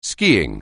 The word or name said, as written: skiing